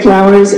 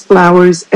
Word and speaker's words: Flowers: I'm Mary Flowers, and I'm here representing the Montana Smart Growth Coalition. My name is spelled M-A-Y-R-E, and my last name is Flowers, F-L-O-W-E-R-S.